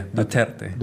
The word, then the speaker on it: Duterte: It's still Duterte, right? – Still am I pronouncing that right? – Yeah, Duterte.